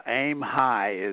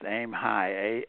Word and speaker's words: AIMHE: Yeah, AIMHE is how it's pronounced, AIMHE, A-I-M, okay, and then H-E, but it's AIMHE, and it's accessible instructional materials in higher education, A-H-E, higher education.